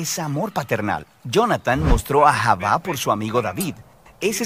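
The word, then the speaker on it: ahavá (אהבה): Se pronuncia ahavá.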